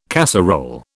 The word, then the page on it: casserole: British English